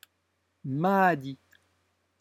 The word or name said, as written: mahdi